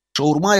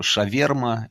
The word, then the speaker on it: шаурма: Ну, во-первых, я сразу всем расскажу, как правильно произносится на самом деле это слово. Шаурма – это по-арабски. В Петербурге есть свое видение, значит, своя версия этого названия называется. Это шаверма, это мы с вами знаем.